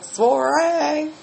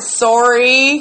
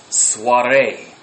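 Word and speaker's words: soirée: I call it sorry. He calls it soiree. So she doesn't do those foreign language pronunciation thingies real well. – I'm not French. – Soiree. It's soiree.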